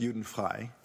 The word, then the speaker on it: Judenfrei: Juden-free, it's actually pronounced Juden-frei, which means free of Jews.